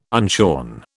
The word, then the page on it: unshorn: British English